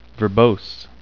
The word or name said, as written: verbose